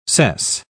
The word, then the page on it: cess: British English